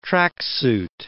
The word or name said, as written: tracksuit